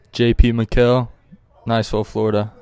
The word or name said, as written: JP Mikhael